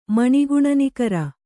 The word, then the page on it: ಮಣಿಗುಣನಿಕರ: maṇi guṇa nikara